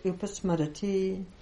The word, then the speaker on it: upasmarati: Chaitad upasam harati. Oops, sorry. I always say that wrong. Chaitad upasmarati.